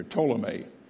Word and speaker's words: Ptolemy: And then there was the one called Ptolemy who took Egypt down here, Ptolemy, spelled with a P, P-T-O-L-E-M-E-Y.